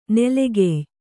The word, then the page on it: ನಲ್ಗೆಯ್: Kannada